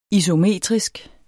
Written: isometrisk

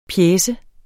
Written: pjece

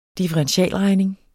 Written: differentialregning

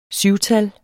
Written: SYVTAL

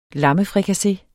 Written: LAMMEFRIKASSE

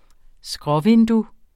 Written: skråvindue

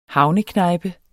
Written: havneknejpe

